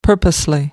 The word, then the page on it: purposely: American English